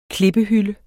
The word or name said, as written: klippehylde